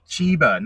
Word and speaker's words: Cheba: And it's pronounced Cheba Hut. – Cheba, not Cheba.